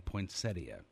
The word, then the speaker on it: poinsettia: The standard pronunciation throughout the country, preferred pronunciation, poinsettia.